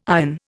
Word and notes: ion: Indian

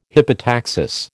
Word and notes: hypotaxis: American English

